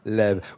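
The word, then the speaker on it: Laives: Ça s'écrit L-A-I-V-E-S. C'est ça. Lève.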